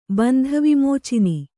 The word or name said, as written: ಬಂಧವಿಮೋಚಿನಿ